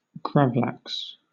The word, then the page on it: gravlax: British English